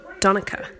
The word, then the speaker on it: Donnacha: So Irish boys' names, some of the correct pronunciations for them would be, for example, Donica, which is D-O-N-N-A-C-H-A. And then Cahal, which is C-A-T-H-A-L.